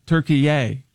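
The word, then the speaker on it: Türkiye: So he immediately demanded that it be pronounced Turkey-ay.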